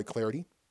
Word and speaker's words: Clardy: And give me the follow, of course, at Troy Clarity. At Troy Clarity, the last name is spelled C-L-A-R-D-Y.